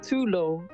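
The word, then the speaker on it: tulou: And Tulo, we can use it, Tulo is pronounced Tulo.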